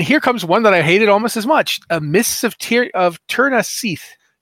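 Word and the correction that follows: Scithe: Seath, I don't know how to pronounce these words. – Scythe.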